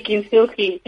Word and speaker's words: Kintsugi: Kintsugi. No sé exactamente si se dice así. – Hola, sí, Kintsugi. Así se llama.